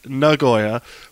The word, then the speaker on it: Nagoya: I learned how to say it right from some people that live there. Nagoya, which is the closest city to the racetrack.